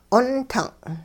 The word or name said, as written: 'ontung'